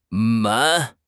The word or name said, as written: men2